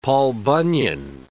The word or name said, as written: Paul Bunyan